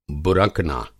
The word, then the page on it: बुरकना: Hindi